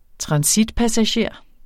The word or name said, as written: transitpassager